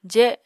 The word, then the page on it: je: apatani